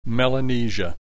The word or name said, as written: Melanesia